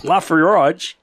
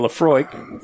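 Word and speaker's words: Laphroaig: Okay, episode 172, Taramusa instead of Taramasu, Lafroyage instead of Lafroig, Paulella instead of Paella by Gusto Eroa instead of Gusto Eroa, Gelato instead of Gelato.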